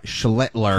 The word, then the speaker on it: Schlittler: Like, the Sox might be able to pitch their way to a victory, whether or not they have to go against Cam Schlittler, who has a point. Yes, I said it correctly. – Careful. – Cam Schlittler.